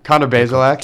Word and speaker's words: Connor Bazelak: I'm actually not sure how do you pronounce that? – Connor Bazelak.